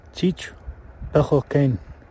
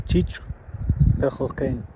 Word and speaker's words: Cheech Pechokane: As you might recall, it is Bald Eagle House in translation, but here is audio of how to say it in the Sealk language. – Cheech Pechokane. Cheech Pechokane.